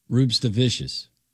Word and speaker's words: Rupstavicius: Emphasize the S. Emphasize the C. – Yes. – Rupstavicius.